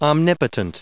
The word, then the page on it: omnipotent: om*nip*o*tent; American English